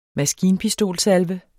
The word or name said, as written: maskinpistolsalve